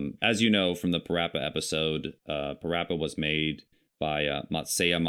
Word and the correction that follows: Masaya: Matsura. I believe Masaya. I can't pronounce his first name. – Oh, Masaya. – So it was made by Masaya Matsura.